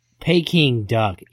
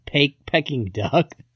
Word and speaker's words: Peking Duck: Peking Duck, idiot, not pecking duck. – I said picking duck. – Well, how'd they spell it? – Like picking, P-I-C-K-I-N-G.